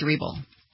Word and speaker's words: Hribal: The stabber is named Alex, and another name with the too many vowels, H-R-I-B-A-L. Hairball? Hairball? – H-R-I-B-A-L. – I believe it's Hreball. – Hreball? – Yeah, it's kind of a silent H, I believe.